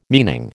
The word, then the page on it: meaning: American English